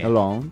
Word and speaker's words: Lens: Long. – Porque la S al final tampoco se debería pronunciar.